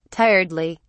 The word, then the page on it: tiredly: British English